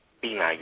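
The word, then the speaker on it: Binah: How did you pronounce it? – Bina, yeah, that's right.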